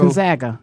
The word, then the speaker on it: Gonzaga: Gonzaga, how you pronounce that? – Gonzalez, or I'm not sure who. – Gonzaga. Yeah, they a young lady reached a milestone, 2,000 points and 1,000 assists in her career.